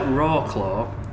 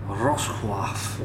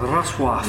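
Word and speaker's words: Wrocław: Looks like Rawclaw, but it's actually pronounced something more like Rosław. – Yeah. – That's really good. – Bless you. – Say that. – Rosław.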